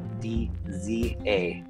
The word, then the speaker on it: Dzadek: Christian, and my last name is DZADEK.